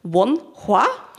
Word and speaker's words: Wonhua: Spreche ich das? Ihr wisst es, mir ist es immer so wichtig, alles richtig auszusprechen. Wonhua? – Ja, stimmt das? Das stimmt voll. – Sehr gut. Yes. – Ja, die Aussprache ist perfekt.